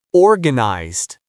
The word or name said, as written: organized